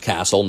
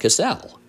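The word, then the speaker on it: Kastle: You probably know if you're listening to this, my last name is spelled with a K, and it is pronounced Castle, not Cassell.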